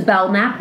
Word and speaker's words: Belknap: Anyways, it was just really Belknap, and it was really hard because there's a silent K. – Okay. – So it's Belknap, but it's a silent K in the fucking middle of my name.